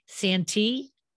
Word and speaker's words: Santi: And today, my guest is Angela, and I'm hoping I'm pronouncing it properly, Santee? – Yes.